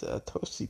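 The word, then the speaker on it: Atoci: It's a Tosi, too. A Tosi is spelled A-T-O-C-I, A-T-O-C-I.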